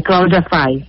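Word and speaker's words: Cláudia Fay: Professora, agora eu fiquei na dúvida, porque eu esqueci de te perguntar antes, mas é Cláudia Fai ou Cláudia Fei? – É Cláudia Fai. – Fai, então acertei. – É. Acertou. – Eu olhei teu nome, se escreve F-A-Y, eu cheguei, bah, será que não tem uma origem aí do francês?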